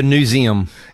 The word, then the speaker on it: Newseum: But you worked for Newseum, is that how you pronounce it? – Yeah. – You came from? – The Newseum.